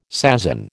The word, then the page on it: sasin: American English